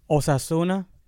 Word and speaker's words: Osasuna: Osasuna.